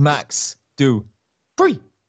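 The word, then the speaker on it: Max Dupri: No, wait, say it right. It's Max Dupree.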